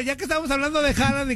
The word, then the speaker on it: Haaland: Dicen que su pronunciación es Haaland.